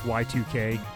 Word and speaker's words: Y2K: I like to call it Yik, but it's pronounced Y2K.